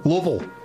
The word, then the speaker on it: Louisville: I said that right, didn't I? Louisville? Louisville? Louisville. – There you go.